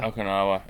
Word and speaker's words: Okinawa: Okinawa. Hey, good job. You pronounced that correctly.